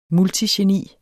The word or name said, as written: multigeni